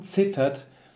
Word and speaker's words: zittert: So und zittert. This is an exception because the the Z Mhm. – really the the, the uh, pronunciation starts with a T.